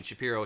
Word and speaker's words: Shapiro: Shapiro, hero, it rhymes.